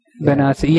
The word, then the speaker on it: Benassi: Is this the Benny Basse? Am I pronouncing that right? – Benassi? Yeah, I think it is, yeah.